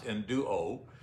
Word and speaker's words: enduo: So when writing about this, the phrase comes from the Greek enduo, which is pronounced enduo, and it infers a sense of sinking into a garment.